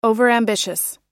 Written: overambitious

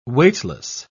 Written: weightless

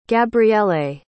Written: gabriele

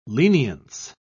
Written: lenience

